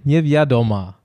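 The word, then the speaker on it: Niewiadoma: Nieviadoma.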